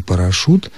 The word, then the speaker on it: парашют: Произносится здесь, конечно, твердо, согласно, «ш» мы можем произнести только твердо. И поэтому хочется написать после этой буквы «у». В словах «брошюра», «жюри» и «парашют» мы, тем не менее, пишем букву «ю».